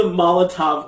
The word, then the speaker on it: Molotov: Did you call it a Mazel Tov cocktail? – I know, I said it wrong. I always say it wrong. I'm like, Mazel. How exactly do I say it? – It's a Molotov cocktail.